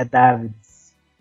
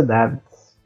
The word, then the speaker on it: Davids: É Davids. Não, eu nunca, nunca pesquisei, não. Eu tô perguntando aqui. – Sim, na pronúncia holandesa mesmo é Davids.